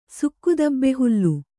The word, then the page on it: ಸುಕ್ಕುದಬ್ಬೆಹುಲ್ಲು: sukku dabbe hullu